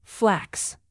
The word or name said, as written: flax